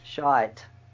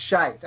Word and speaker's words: shite: When someone adds an E to S-H-I-T, is that pronounced differently? – Shite. – Yes. It's shite.